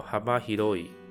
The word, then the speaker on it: 幅広い: 那第三个汉字有一个福，服务员的福啊，还有广大的广，那念作哈巴希罗伊，哈巴希罗伊就是大范围的意思啊，所以这边从车站结构这边开始念作 aki kona ni aru jido hanbai ki ni kawakiri ni，就是这类型的电子钱包。